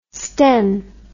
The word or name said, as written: sten